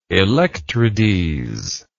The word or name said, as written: electrides